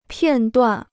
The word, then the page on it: 片断: piàn duàn